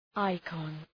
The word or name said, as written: icon